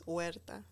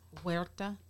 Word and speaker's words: Huerta: So you don't pronounce the H, so it's Huerta. – Huerta?